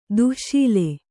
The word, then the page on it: ದಾಸಳ: dāsaḷa